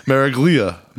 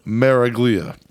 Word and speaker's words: Miraglia: Emilio Miragalli. – Miraglia.